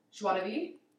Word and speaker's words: joie de vivre: The joie de vivre.